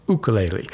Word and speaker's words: ukulele: For one, the true Hawaiian pronunciation of ukulele is to is to use the starting sound of uk as in spook instead of the hard u sound. In other words, ukulele.